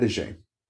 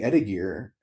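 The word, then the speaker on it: étagère: It's called an etagé. And the way it's spelled is etagere. And she kept saying etagere and I kept connecting her to etagé.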